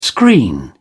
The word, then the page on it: screen: British English